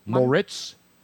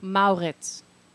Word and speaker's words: Moritz: Moritz Seider. – Mauritz. – I'm going to say Moritz, like Mauritz. Sandmaritz. – German would be Mauritz.